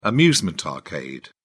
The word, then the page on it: amusement arcade: British English